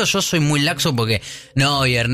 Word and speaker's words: Neuer: Me acabo de dar cuenta que es el arquero que yo pronuncié muy mal. – Neuer. – Neuer. Y yo dije Neuer. Sí. Y es Neuer. – Neuer.